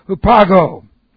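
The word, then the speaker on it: Hupago (ὑπάγω): And this is with breathing, so H-U-P-A-G, long O. Hupago.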